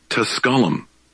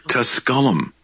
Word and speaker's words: Tusculum: Tusculum. Tusculum. – And Division Tusculum played Furman last night, and I'm going, yep.